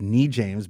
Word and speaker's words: Noujaims: So he's Lebanese, and it's a great restaurant, and it's Nou James, but it's spelled Noujaims restaurant, and it's on Main Street in Winstead, which is 44.